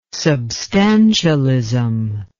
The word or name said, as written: substantialism